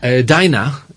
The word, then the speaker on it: diner: Diner es un lugar donde podemos ir a comer en Estados Unidos. Y se pronuncia diner. Pero cuando estamos pidiendo la cena, decimos dinner. – Dinner. – Son dos palabras que se escriben igual, pero se pronuncian diferente dependiendo de si estamos pidiendo la cena o es un establecimiento al que vamos a comer.